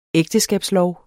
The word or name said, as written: ægteskabslov